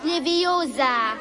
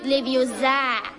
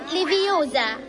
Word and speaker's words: Leviosa: Und übrigens hast du die falsche Betonung. Es heißt Leviosa und nicht Leviosa. – Los, mach du es doch, wenn du so super schlau bist. Na los, mach. – Wingardium Leviosa.